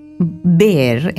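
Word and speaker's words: Bär: Mi papá se llamaba Erico Behr, en realidad, porque esos dos puntitos en la en alemán se pronuncian como una E.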